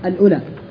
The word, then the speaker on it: الأولى: هي كذا تنطق الأولى, أنت لا تحتاج أن تقول واو, إذا قلت الواو ستمت ستقول الأولى.